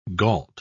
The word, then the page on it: gault: British English